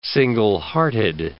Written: single-hearted

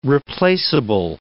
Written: replaceable

